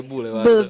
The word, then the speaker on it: Boulevard: Ah, il pezzo si scrive BLVD. – Ok. dovrebbe essere Boulevard.